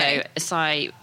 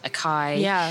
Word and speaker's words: açaí: So açaí, people might have heard mispronounced in the UK as acai,